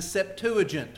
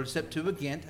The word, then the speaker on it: Septuagint: In your study, or maybe in a sermon, you have heard me or another preacher reference something as the Septuagint, or Septuagint, however you want to pronounce that G.